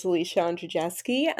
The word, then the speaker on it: Alicia Andrzejewski: So as you pronounced beautifully, my name is Alicia Andrzejewski.